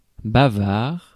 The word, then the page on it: bavard: /ba.vaʁ/; France